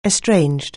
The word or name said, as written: estranged